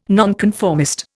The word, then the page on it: nonconformist: Indian